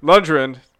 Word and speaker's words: Lundgren: Disney passes on Sylvester Stallone and Dolph Luke. I always mispronounce this guy's name. – Lundgren.